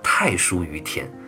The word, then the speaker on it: 大叔于田: 所以啊，又跟我们这里的这一首《大叔于田》好像有点关系了。有人说啊，这个“ 大叔于田” 的“ 大” 字啊，也应该读成“ 太”，所以叫“ 太叔于田”。这个“ 太叔” 呢，指的就是公叔段。而且啊，古代的诠释啊，基本上都是将“ 叔于田” 和“ 大叔于田” 这两首诗歌和庄公克段的故事结合起来理解的。